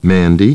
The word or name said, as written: Mandy